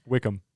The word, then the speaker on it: Wickham: Is it Wickman? – Wickham. – Wickham. That's what I said. Wickham. – Yeah. It's not pronounced Wickham. Oh, sure. – It's spelt that way.